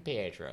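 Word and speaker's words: Paetro: Did I just pronounce Pietro so well? – You, it was twice. Perfect. I know, it was great.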